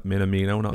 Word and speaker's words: Minamino: Minamino. – Yeah, Minamino.